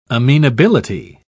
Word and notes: amenability: British English

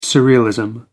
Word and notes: surrealism: British English

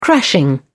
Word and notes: crashing: British English

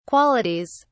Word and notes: qualities: British English

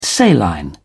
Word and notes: saline: British English

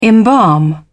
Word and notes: embalm: British English

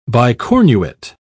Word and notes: bicornuate: British English

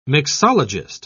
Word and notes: mixologist: British English

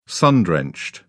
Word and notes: sun-drenched: British English

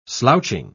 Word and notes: slouching: British English

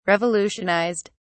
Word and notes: revolutionised: British English

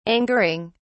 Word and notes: angering: British English

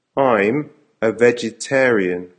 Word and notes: I'm a vegetarian: American English

